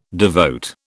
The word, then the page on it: devote: British English